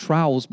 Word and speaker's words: trowels: and we use these things called trowels and I love uh, it's actually pronounced trials, but I want to call them a trial.